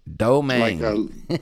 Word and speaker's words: Domingues: It's Domingues. – Domingues. – Like lemon meringue.